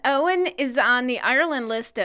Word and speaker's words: Eoin: Okay, next one, E-O-I-N. – Ion. – Ion? – It's pronounced Owen. – Oh. – Owen. – As in the actor, like, Owen Wilson or Clive Owen. Exactly like that.